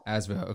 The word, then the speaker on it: ASVEL: Asvel. – Okay. So playing for Asvel over there in Euro League midseason right now.